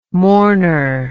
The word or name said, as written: mourner